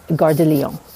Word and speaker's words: Gare de Lyon: For instance, Google has a British woman, or the pronunciation is Gare de Lyon, which is how it's pronounced.